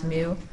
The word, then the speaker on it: mu: Mu is a Greek letter. It looks like a cursive U, like that. It's pronounced mu.